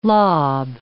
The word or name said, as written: lob